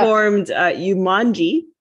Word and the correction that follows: Ujamaa: Am I saying that right? Ujamaa, or I think I'm saying it wrong, Ujamaa. But Ujamaa, sorry.